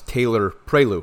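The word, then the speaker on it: Taylor-Prioleau: Another name is Alex Taylor Prelu. I believe I'm pronouncing that right. Spelled T-A-Y-L-O-R hyphen P-R-I-O-L-E-A-U.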